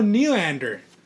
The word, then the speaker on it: Nylander: I get mad when you talk about Nylander.